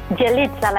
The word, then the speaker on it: Yelitsa: Yelitsa, like pizza.